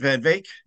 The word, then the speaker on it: van Wyk: Van Vaak? – Yeah, that's pretty good. – The W is like German, you know, you don't pronounce, the W's are pronounced as V's.